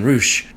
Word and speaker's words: Reusch: Nathan Roush, rhymes with swoosh,